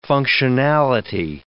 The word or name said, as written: functionality